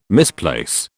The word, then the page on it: misplace: British English